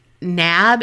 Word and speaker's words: Knabe: Some people say Nab because it's K-N-A-B-E, but a lot of people had said Kanabe.